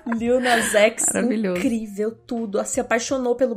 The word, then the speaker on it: Lil Nas X: Como que pronuncia? Lil Nas X, é isso? – Lil Nas X. Nossa, Lil Nas X!